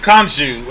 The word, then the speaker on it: Khonshu: It has the two of them and Gold, or like some like retroactive, like old school version of Nanshu, of Moon Knight's god. – Yeah, kind of. Did you say Nanshu? – I think so. K-N? – I think it's K-H. I've always pronounced Khonshu.